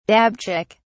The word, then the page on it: dabchick: English; British English